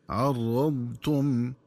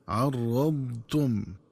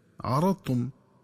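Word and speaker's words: عرّضتم: ينبغي التنبه للنطق الصحيح للفظ عرضتم، فيقرأ بالراء وتشديدها هكذا عرضتم، مع مراعاة استطالة الضاد عرضتم. ومن الخطأ قراءتهم بفتح الراء وتخفيفها هكذا عرضتم، ومن الخطأ كذلك قراءتها بترك استطالة الضاد وإدغام الضاد في التاء فتصير طاء هكذا عرضتم.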